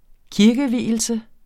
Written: kirkevielse

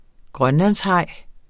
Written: grønlandshaj

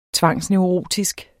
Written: tvangsneurotisk

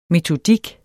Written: metodik

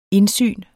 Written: INDSYNENE

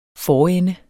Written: forende

